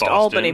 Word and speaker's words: Albany: Right, which is a state, and it's pronounced Albany, people, not Albany.